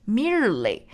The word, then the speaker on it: merely: 在美语当中，注意要把这个日的音 mirror，mirror，merely 啊。那像国际音标就会念成 mere，mere，merely 啊。但是美语就是 mirror 没有这个把变成日 merely。